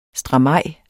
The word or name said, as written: stramaj